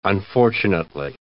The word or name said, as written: unfortunately